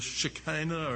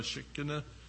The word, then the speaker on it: Shekinah: The tabernacle, according to the Bible, was the portable dwelling place for the divine presence, or the Shekinah, or Shekinah, depending on how you want to pronounce it, from the time of the Exodus from Egypt through the conquering of the land of Canaan.